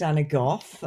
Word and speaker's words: Anna Gough: My name is Anna Gough.